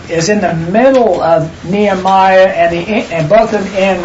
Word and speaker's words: Nehemiah: H-E-M is where we have it in four syllables. It's only three. So N-E hyphen H-E-M, which would be Ham. And then Y-A-H would be Yah. Mm-hmm. So it's really Nehemiah is his name.